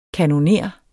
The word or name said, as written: kanoner